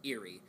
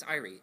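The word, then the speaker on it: Éire: Because basically he's like, all right, if Kyle's like, if I win, you have to go back to the land of my father, Eerie. And Seamus is like, Irie, but okay. And then he keeps saying Eerie. And finally Seamus just goes, could you just say it right once, please? It's Irie.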